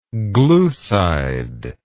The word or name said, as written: glucidic